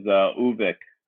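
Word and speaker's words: Övik: No, not perfectly, but I'm going to go with the short form, which is Uvik.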